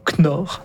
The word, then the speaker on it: Knorr: Alors, je ne suis pas exactement sûr comment on prononce K-N-O-R-R, mais on va dire Knorr. – Pas de K.